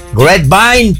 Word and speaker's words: Grapevine: Grapevine, Texas.